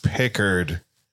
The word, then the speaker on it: Picard: I said Picard, and Gregor rolled his eyes. We were on the air together. Whatever. Small flex. And he went, it's Pickard.